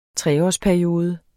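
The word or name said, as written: treårsperiode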